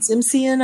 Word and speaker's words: Simsion: Simsion, I think, Simsion. Is how you say it.